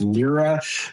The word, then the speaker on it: LIRA: Now, a locked-in retirement plan, there's an acronym, it's LIRA, it's pronounced LIRA.